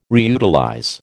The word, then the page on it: reutilize: American English